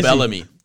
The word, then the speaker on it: Bill Bellamy: Bill Bellamy.